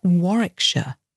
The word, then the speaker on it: Warwickshire: So it looks like War, W-A-R, Wick, W-I-C-K, and then Shire on the end. And Warwickshire, it's got a silent W in the middle.